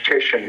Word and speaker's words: Titian: Am I saying his name correctly? – Reg Titian, yes. – Titian, I'm sorry.